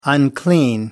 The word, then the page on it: unclean: American English